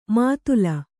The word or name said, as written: ಮುತ್ತಲು